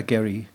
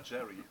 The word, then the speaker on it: Gerry: Also das ist ja am Ende, ich würde mal sagen, sogar hier, besetzt bei Jerry the, the Pacemakers oder Gary. Jerry oder Gary? Wie spricht man so? Ich habe ein Video gesehen, da sagt er selber Jerry.